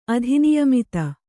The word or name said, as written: ಅಧಿನಿಯಮಿತ